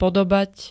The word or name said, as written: podobať